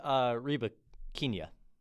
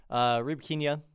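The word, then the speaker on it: Rybakina: And she's facing Reba Kinya. Yes, that's how you say her name. Anyways, Reba Kinya, if she wins, and well, she's ranked number two right now.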